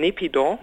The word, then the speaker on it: Naypyidaw: Und Sie haben es vorhin schon angesprochen, Naypyidaw, habe ich das richtig ausgesprochen, die Hauptstadt von Myanmar? – Ja, Naypyidaw.